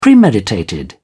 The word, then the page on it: premeditated: British English